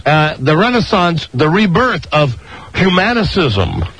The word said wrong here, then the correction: humanism: I don't know how to pronounce it. – No. Spell it. H-U-M-A-N, humanism. – Humanism.